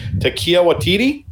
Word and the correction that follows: Taika Waititi: Taika Waititi.